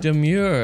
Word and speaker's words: Domergue: Am I saying that right? – Demure, according to her. – Is it really? – Yes, to her. – So it's spelled D-O-M-E-R-G-U-E. And it's, how do we say it? – According to her, she seems to want it pronounced Demure.